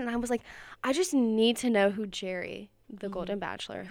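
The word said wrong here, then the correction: Gerry: Gary. – Oh, wait. Is it Gary? – You said Jerry. – Is it Gary, though? – It's Gary. – Okay. It's spelled G-E-R-R. G-E-R-R-Y.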